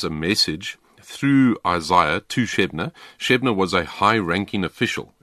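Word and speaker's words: Shebna: Yeah, Shebnah.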